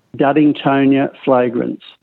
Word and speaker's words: Duddingtonia flagrans: Duddingtonia flagrans.